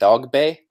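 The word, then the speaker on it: Dogboe: Now they're all pronouncing it Dogbe. I guess that's the correct pronunciation, yeah. Even though it's spelled Dogbo.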